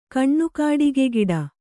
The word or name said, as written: ಕಣ್ಣುಕಾಡಿಗೆಗಿಡ